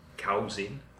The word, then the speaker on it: cousin: And also be careful with the pronunciation of that word, listeners, because the spelling is a little bit difficult and doesn't really explain how to pronounce it. C-O-U-S-I-N, which a Spanish speaker may say, cousin. it's a. The same vowel sound, a, as in uncle, is the sound in the first part of cousin.